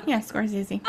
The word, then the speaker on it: Scorsese: Scorsese, did I say that right? – Scorsese.